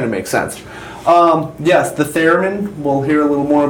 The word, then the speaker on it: theremin: How do we pronounce that? Theremin? – Theremin.